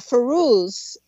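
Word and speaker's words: Fairuz: And by the way, Dave, Ferouz is how you pronounce that name.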